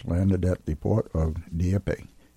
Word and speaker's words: Dieppe: Uh, a village near Dieppe, which uh, was Dieppe, one, Dieppe.